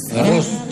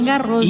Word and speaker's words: Garros: Garros. Es una excepción a la regla de que no se pronuncian las S. – Claro. – Garros. – Es Garros.